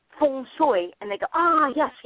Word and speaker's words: feng shui: the way it is pronounced in China, where it's more like F-O-N-G-S-O-Y, feng shui.